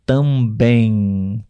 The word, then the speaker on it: também: O M no final das palavras nós não pronunciamos com os lábios tocando um no outro, né? Como a gente aprende a uhum. pronunciar uhum. o M. Por exemplo, também, também ok?